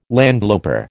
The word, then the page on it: landloper: American English